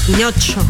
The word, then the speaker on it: Pinocho: Pinocho. – Grave. Pinocho es grave. Tiene el acento en la anteúltima sílaba, como todas las palabras grave.